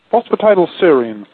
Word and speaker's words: phosphatidylserine: And one of the first things, as you can see, was phosphatidylserine. How do you say it? – Phosphatidylserine.